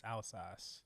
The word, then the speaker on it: Alsace: Vendalsas. I was thinking about Versace. – Vendalsas. Vendalsas. Vend what? All it's Alsace.